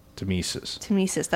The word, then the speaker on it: tmesis: And it's spelled T-M-E-S-I-S. Tamesis.